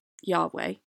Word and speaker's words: YHWH: The tetragrammaton is easy enough to understand, you're looking at a verse that contains that four-letter name of God, which today is most frequently pronounced Yahweh, and in English is written YHWH, and in Hebrew is written Yud-Heh-Waw-Heh.